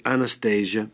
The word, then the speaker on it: Anastasia: perché si scrive Anastasia, ma si legge Anastasia, che ci fa, ci dice qualcosa su un giorno nella tua vita.